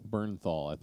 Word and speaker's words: Bernthal: Bernthal, is the way they pronounce it.